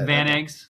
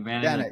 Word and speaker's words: Vanags: I get usually Vanags or something like that, but it's Vanags, like Vanags, yeah. Like a van and two eggs.